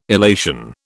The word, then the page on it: illation: British English